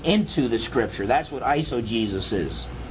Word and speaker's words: eisegesis: Eisegesis, it's spelled Eisogesis, but it's pronounced just like Jesus, so a lot of people misspell it if they just hear it.